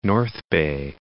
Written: North Bay

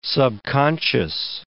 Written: subconscious

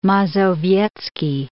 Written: Mazowiecki

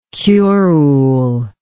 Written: curule

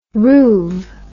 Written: roove

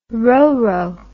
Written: roro

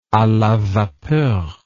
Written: a la vapeur

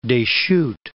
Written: Deschutes

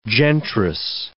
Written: gentrice

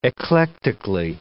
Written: eclectically

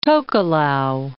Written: Tokelau Islands

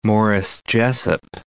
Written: Morris Jesup, Cape

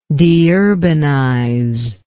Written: deurbanize

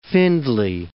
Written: Findlay

